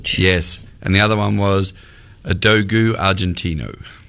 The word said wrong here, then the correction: Dogo: And excuse my pronunciation for anyone out there, though that may be incorrect. D-O-G-O Argentino. – Okay, Dogo.